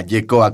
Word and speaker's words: Yehcoa: C O A YECOA, colectivo YECOA, hablando de identidades aquí en Radio UNAM.